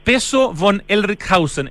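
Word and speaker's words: Pezo von Ellrichshausen: Peso von Ehrlichhausen. ¿Está bien pronunciado o es von, es von? ¿Cómo se pronuncia, Pablo Altiques? – No, lo pronunciaste impecable, súper bien.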